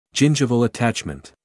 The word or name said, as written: gingival attachment